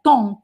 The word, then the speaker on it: temps: Tom, tom, tom, tom, tom, tom e mas nós escrevemos TEMPS, né?